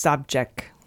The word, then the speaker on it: Savchuk: Her name is pronounced Sobchak.